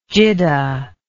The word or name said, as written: Jidda